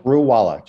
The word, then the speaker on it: Screwvala: Screwvala, just a silent V.